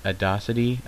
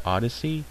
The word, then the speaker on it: Audacity: Is that Ad Audacity? I, I've never known how to pronounce it. I would always pronounce it Odyssey, but it's Audacity.